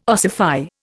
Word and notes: ossify: Indian

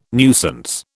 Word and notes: nuisance: British English

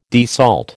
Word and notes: desalt: American English

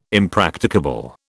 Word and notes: impracticable: British English

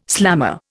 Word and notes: slammer: Indian

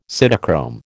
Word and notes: cytochrome: American English

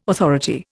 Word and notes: authority: Indian

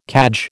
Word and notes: cadge: American English

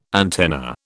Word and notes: antenna: British English

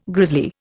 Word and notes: grisly: Indian

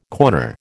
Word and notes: corner: American English

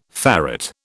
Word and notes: ferret: British English

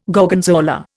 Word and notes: Gorgonzola: Indian

